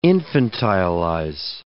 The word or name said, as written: infantilize